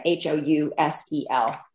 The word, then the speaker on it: Housel: Housel, like house with an L at the end of it.